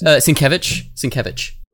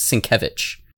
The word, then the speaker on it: Sienkiewicz: Sienkiewicz. – Really? – Yeah. It's spelled Sienkiewicz, but it's Polish, so it's pronounced Sienkiewicz.